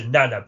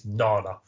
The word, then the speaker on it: Nana: I actually thought Nana visitors, because that's the thing, we always used to pronounce her Nana, but actually we found out recently you actually pronounce her first name is Nana.